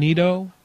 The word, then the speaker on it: Nito: Lumber department is Nito. How can I help you? – Hey, what's your name? Nito? – Yes. – How you spell that? – N-I-T-O.